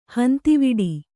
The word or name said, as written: ಹಂತಿವಿಡಿ